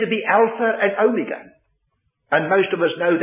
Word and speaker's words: omega: It's the long O, omega. There's a short O, omicron. O, micron, microscopic. O, little O. O, mega, megaphone, a loud voice, large O.